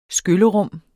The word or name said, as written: skyllerum